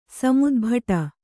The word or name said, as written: ಸಮುದ್ಭಟ